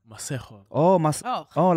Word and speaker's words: Maségo: Tell us how it's supposed to sound if you're trying to sound extra African. – Maségo.